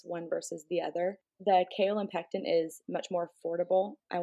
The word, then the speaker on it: kaolin pectin: And then that product I had to look up, I always say it wrong. It's kaolin pectin.